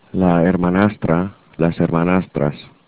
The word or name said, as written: hermanastra